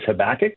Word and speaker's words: Habakkuk: The book of Habakkuk, and I know that a lot of people pronounce that a little differently. I think the traditional understanding is the idea of pronouncing this Habakkuk, not Habakkuk. But anyway,